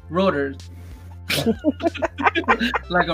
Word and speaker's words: Reuters: Reuters. Like a rotor cuff.